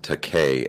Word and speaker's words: Takei: No, he pronounces it Takei, like, really? – I'm gay. That's what he said. During the roast of William Shatner, he goes in, it's pronounced Takei as in I'm gay.